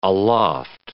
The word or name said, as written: aloft